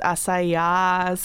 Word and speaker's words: Assayas: Olivier Assayas, yeah, which you should always pronounce as Assayas.